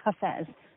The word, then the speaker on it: Hafez: American English tends to pronounce his name Hafiz because I guess we understand the I pronounces our American eyes, but it's actually Hafez. So just wanted to clarify in case anyone was walking around uncertain as to how to pronounce this great Sufi mystic poet's name.